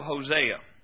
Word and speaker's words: Hosea: Now, go back, please, to the book of Hosea. The book of Hosea. We pronounce it Hosea. If you call it Hosea in Israel, they'll laugh at you. Hosea was not a Mexican prophet. It's Hosea.